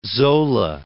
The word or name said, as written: Zola